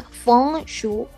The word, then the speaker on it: Feng Shui: Dato curioso, en chino Feng Shui se pronuncia Feng Shui.